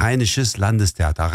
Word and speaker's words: Rheinisches Landestheater: Şimdi evet, ardından benim için telaffuzu zor olan Rheinisches Landestheater, söyler misiniz? – Evet, Rheinisches Landestheater.